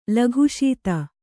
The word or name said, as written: ಲಕ್ಷಿತ